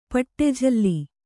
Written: ಪಟ್ಟೆಜಲ್ಲಿ

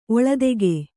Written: ಒಳದೆಗೆ

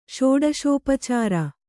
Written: ಷೋಡಶೋಪಚಾರ

